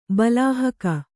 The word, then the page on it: ಬಲಾಹಕ: Kannada